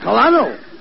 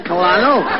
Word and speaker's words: Colonel: Colonel. You dummy, that Colano, that's a pretty high rank. – No, not Colano. – It is. – No, you see, that word is pronounced Colonel.